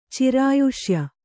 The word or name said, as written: ಚಿರಾಯುಷ್ಯ